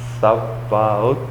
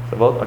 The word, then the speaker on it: Sabaoth: And this is where it uses, and I had trouble pronouncing this before, Shauna helped me, Jehovah Sabaoth, you see, Lord of Hosts, how did we say it before? Sabaoth. Sabaoth, okay, thank you.